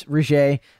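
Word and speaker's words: Regé-Jean: It looks like Reggie to me, but obviously it's Regé. It's obviously not.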